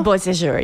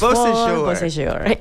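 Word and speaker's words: Beau Séjour: Is that how we're pronouncing it now? Sejour, yes. – Beau Sejour in Aulac.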